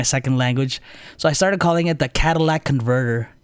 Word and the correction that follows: catalytic converter: but it's actually the catalytic converter.